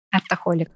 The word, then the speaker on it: Артохолик: Расскажите, что такое артоголик или как правильно произносить? Артохолик.